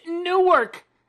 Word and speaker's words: Newark: Which is bullshit, by the way. It's pronounced Newark. – Yes. – Newark. – Listen, okay. – Sounds like a squawky bird.